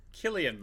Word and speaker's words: Cillian: Is it Cillian Murphy or Killian Murphy? – Killian Murphy. And I believe that I did correct you on this.